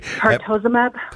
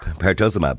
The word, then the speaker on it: Pertuzumab: Pertuzumab. – Pertuzumab.